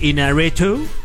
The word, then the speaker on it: Iñárritu: Iñárritu. No sé si lo estoy pronunciando bien. – No, muy bien.